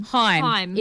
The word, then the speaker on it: Haim: Is it Haim or Haim? – Haim. It is Haim.